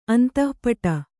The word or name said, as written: ಅಂತಃಪಟ